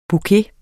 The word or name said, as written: bouquet